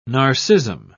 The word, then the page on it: narcism: British English